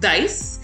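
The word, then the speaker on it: DAICE: And, you know, despite the hyphen in the group's name, it is pronounced Dice.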